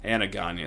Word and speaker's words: Anagonye: remember Aloysius Alagonye, and gave me a weird look, like, it's Anagonye.